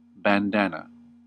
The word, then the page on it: bandana: American English